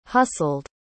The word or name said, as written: hustled